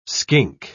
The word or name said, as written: skink